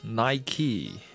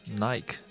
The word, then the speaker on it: Nike: Actually, this is Nike. It's not pronounced Nike. OK，嗯，其实这个耐克品牌呢，刚进中国的时候，按照呃标准英语发音的规则都会读 Nike，but actually it's Nike. So remember Nike, Nike, Nike.